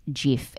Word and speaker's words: GIF: The guy who created it said it's actually meant to be pronounced GIF. It's not a hard G.